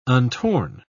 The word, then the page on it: untorn: British English